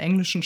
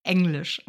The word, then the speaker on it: Englisch: Ist ein sehr häufiger Fehler, einfach weil es in der englischen Sprache selbst nicht als ausgesprochen wird, ja, sondern eher ing. Aber es ist Englisch. – Englisch, wie ähnlich.